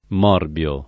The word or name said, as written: Morbio